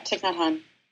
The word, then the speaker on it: Thich Nhat Hanh: This is from Thich Nhat Hanh, if I'm saying that right. – Yeah, Thich Nhat Hanh.